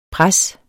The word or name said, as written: pres